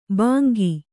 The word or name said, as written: ಭಂಗಿ